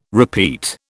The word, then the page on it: repeat: British English